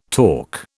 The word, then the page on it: talk: British English